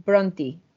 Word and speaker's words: Brontë: Porque es, The Bronte Sisters, las hermanas Bronte, o sea, aquí lo pronunciamos en español, pero en teoría es las hermanas Bronte, pero suena horrible en español, entonces las Bronte.